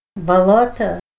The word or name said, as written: balota